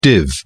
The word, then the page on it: div: British English